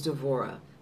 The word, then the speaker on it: Devorah: So I changed it to Devorah. And it's just an easier. And then the other part of that is, when I say my name is Devorah, what do I get back? – Devorah.